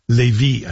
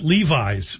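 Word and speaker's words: Lévis: Living in a house full of Quebecers, I should pronounce that properly. Levy, I think, is a better way to see it. Hey, say it. I called it Levi's for a long time, so this is an improvement.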